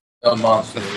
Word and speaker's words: El Monstro: What's am I understanding the nickname of the factory down in Nicaragua is El Monstro, right? I'm probably mispronouncing that. Is that correct? – El Monstro, yeah.